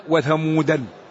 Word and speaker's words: ثمودا: وثمودا، ثمود تصرف ولا تصرف، ولذلك الذي لم يصرفها جعل وراء الدال ألف وعليها دائرة لأنها لا تنطق،